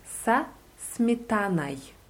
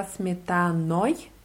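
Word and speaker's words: Sasmetanai (со сметаной): Am Ende ist OJ. Also genau ausgesprochen würde es ähm, sich anhören sa Sasmetanoi, aber man verschluckt das so am Ende ein bisschen und sagt sa Sasmetanai.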